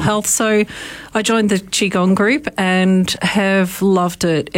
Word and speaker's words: Qigong: I'm going to have a crack at the pronunciation here because it's spelled Qigong, but we pronounce it Qigong.